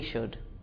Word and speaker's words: should: I should, she should, he should, they should, you should, we should. When we say the word should, the letter L is silent.